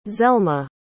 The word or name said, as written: zelma